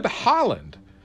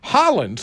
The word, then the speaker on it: Haaland: In a historic first, President-elect Joe Biden will nominate Representative Deb Haaland to lead the Department of the Interior. Haaland, spelled H-A-A-L-A-N-D, so it's not like the Haaland you're used to.